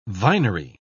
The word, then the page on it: vinery: British English